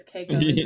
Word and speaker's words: Keiko: Oh my god, I said Kaiko. It's Keiko.